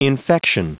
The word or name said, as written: infection